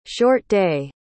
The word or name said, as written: short-day